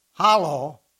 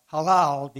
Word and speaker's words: hallel: They would also sing some of these psalms, especially what they call the hallel, or halal, depending on how you pronounce it.